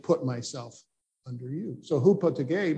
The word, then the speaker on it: hypotage: The word submission, the New Testament, spelled, I should put, it's spelled H-Y-P-O-T-A-G-E in English, but that's not how it's pronounced. It's pronounced hupotage, hupotage.